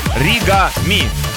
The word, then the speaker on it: Оригами: Это трек называется Ори. Нет, неправильно начал. Оригами. Так лучше расставить ударение.